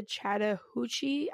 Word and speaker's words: Chattahoochee: Chattahoochee.